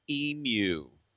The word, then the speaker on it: Emu: Let me tell you something else about Blue Emu. That's right. You hit that U like you're in England. Because they say, they pronounce it long, like stupid and Q8.